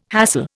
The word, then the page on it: hassle: Indian